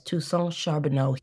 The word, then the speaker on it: Toussaint Charbonneau: Yes, Toussaint Charbonneau.